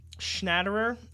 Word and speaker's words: Schnatterer: And then after that, Marc um, Schnatterer, I think, and that's how you pronounce his name, in the 39th, uh, Bayern went into the half down 2 1.